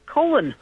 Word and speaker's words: Colon: Let's go now in, I don't know how to say that, Cologne, Michigan. You're going to have to help me with the pronunciation. – Nope, it is just like the punctuation mark, colon.